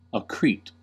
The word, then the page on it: accrete: American English